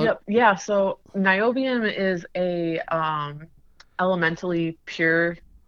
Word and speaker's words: niobium: Is it Neoplume? Did I say that right? – Say that again? – It says pure neobalm, NIOBLUM. – Oh, niobium.